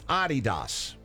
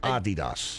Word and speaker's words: Adidas: Americans pronounce Adidas wrong. You know how the right way to say it is? – What? – It's German. It's Adidas. With a slight emphasis on the first syllable. Adidas.